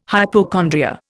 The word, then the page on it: hypochondria: Indian